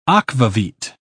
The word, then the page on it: akvavit: British English